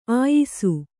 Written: ಆಯಿಸು

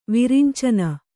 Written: ವಿರಿಂಚನ

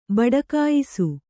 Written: ಬಡಕಾಯಿಸು

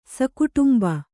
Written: ಸಕುಟುಂಬ

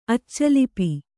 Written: ಅಚ್ಚಲಿಪಿ

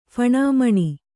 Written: ಫಣಮಣಿ

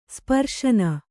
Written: ಸ್ಪರ್ಶನ

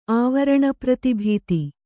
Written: ಆವರಣಪ್ರತಿಭೀತಿ

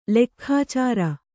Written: ಲೆಕ್ಖಾಚಾರ

